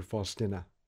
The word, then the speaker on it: Faustina: She's a close friend of mine, and Teresa is named after Faustina. It's her middle name. Now, if you've noticed, I pronounce her name Faustina because a former student of mine from Poland politely reprimanded me for pronouncing it incorrectly.